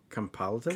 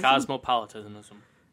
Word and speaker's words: cosmopolitanism: And compolitism? – Cosmopolitanism.